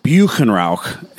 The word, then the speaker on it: Buchenrauch: But when I said Buchenrauch, she was like, no, you're not saying it right. And so she coached me into like a more guttural pronounced version of Buchenrauch. My brewer, Francis, who is not a native but is fluent in German, he has coached us to like truly say Buchenrauch.